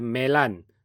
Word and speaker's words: 棉兰: 因此，只要是由福建人来命名的地名哦，大部分读 d 的音呢，都要读成 l 的音。就例如印尼的 Medan 就变成 Melan，那我们就写成棉兰。